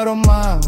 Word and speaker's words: рома: Ни цыгане, а рома, ударение на а.